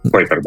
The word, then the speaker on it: Kuiper: Или это Project Kuiper, это по-английски так Kuiper пишется, да? Э, ну, Койпер, да.